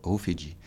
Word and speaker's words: Rufiji: Ça s'écrit en français Rufiji, et ça se prononce là-bas Rufiji.